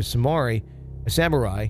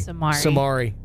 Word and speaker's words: samurai: I always say that incorrectly. – Samari. That's funny. – That's like my fourth grade version of me coming out, because there was a video game called Samurai Showdown, but I didn't know how to pronounce it in fourth grade, so I just called it Samari.